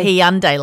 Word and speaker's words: Hyundai: I couldn't say it, could I? – Yeah, you said it wrong. – I used to say Hyundai. – Yeah, same. – And now and we when we did ad. The ad, Hyundai. – Hyundai, like Sunday.